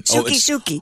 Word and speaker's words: Sookie: When Suck Sue came out. Boing. Suck Sue. – I'm sorry. What was her name? – Suki.